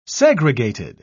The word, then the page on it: segregated: British English